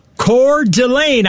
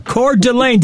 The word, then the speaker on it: Coeur d'Alene: Coeur d'Alene? – Yeah, it's Coeur d'Alene. I checked it this morning. Coeur d'Alene. Did I say that right? – Correct.